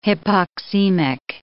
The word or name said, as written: hypoxemic